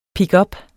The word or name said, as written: pickup